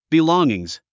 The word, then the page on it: belongings: bilɔ́ŋiŋs; ビロンギングス